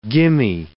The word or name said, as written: gimme